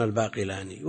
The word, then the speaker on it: الباقلان: يقول والطهارة بالماء الطاهر المطلق الذي لا يضاف إلى اسم شيء غيره مثل ماء الباقلة، الباقلة بالتشديد أو التخفيف، أما بالنسبة للباقلان المعروف وهو منسوب إليها كلهم يجمعون على التخفيف فيقولن الباقلان، وإن كان نطق الناس بالتشديد، والمراد بالباقلة كما في الشروح أو في بعضها أنه إيش؟